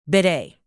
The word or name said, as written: bidet